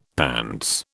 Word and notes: pants: British English

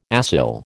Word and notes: acyl: American English

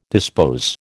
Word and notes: dispose: American English

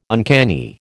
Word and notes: uncanny: American English

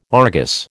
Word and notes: Argus: American English

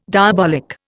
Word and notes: diabolic: Indian

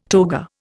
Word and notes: toga: Indian

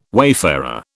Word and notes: wayfarer: British English

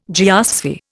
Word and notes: geosphere: Indian

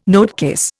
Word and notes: notecase: Indian

